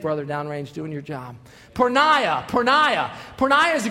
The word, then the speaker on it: porneia: It's a Greek word, and it's pronounced porneia.